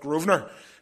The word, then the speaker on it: Grosvenor: It's spelt Grosvenor, but we all just call it Grosvenor, because we're a wee bit common there.